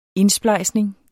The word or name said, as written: indsplejsning